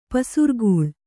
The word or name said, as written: ಪಸಿರ್ಗಲ್